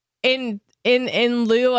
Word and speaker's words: in lieu: We, in lieu of a Thursday guest, did I say that right? In lieu? Yeah.